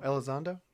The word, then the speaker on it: Elizondo: Hector? Oh, Elizondo? That guy? – Elizondo. That's how you say it. Thank you.